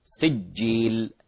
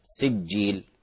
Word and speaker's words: سجيل: لعل يعني نسمع الآن التعطيش أو الفراغ الهوائي في نطق الجيم، جيم خطأ، فهي من شجر اللسان من غير ترك هواء. سجيل سجيل.